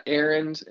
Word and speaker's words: Arend: You can follow me on Twitter at Aaron. My name is spelled A-R-E-N-D. It's weird, but it basically, if you search A-R-E-N-D on social media, I come up.